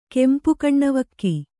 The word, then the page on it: ಕೆಂಪುಕಣ್ಣವಕ್ಕಿ: kannada